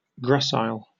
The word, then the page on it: gracile: British English